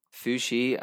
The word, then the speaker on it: Fuxi: So this is called Fuxi and Nuwa, the parents of humanity. Am I saying that right? – You are saying that right. Fuxi and Nuwa, yeah. – Oh, you're going for a Fuxi, yeah. – I went for Fuxi, yeah. I think it's like more Chinese sounding.